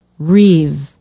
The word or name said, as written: reeve